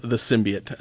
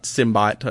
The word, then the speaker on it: symbiote: At least now they showed the symbiote, and that's how you pronounce it, not Symbot or Symbiote, or, yeah, sounds like a robotic version of Simba, the Symbot.